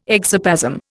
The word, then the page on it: exoplasm: Indian